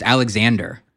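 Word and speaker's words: Alexandre: And they're like, Alexander Farms. It's just Alexander.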